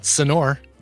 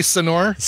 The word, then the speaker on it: señor: You don't pronounce it señor. Hey, señor.